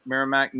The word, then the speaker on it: Merrimack: And the Bearcats take on Merrimack. Am I saying that right? Merrimack? – Yeah, I think so. Is it? – Yeah, Merrimack. Neill, you're on mute.